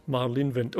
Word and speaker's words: Marlene Wind: Marlene Wind.